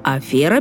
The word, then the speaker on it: афера: А вот слово «афера» пишется и произносится через «е». Это слово пришло к нам из французского «афера», и его написание через «е» зафиксировано в словарях русского языка начала XIX века.